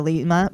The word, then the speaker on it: Lyma: And I did have to look it up on YouTube on how to pronounce it because you can do Lyma or Lyma, but it's Lyma. So it's L-Y-M-A.